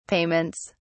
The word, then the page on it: payments: British English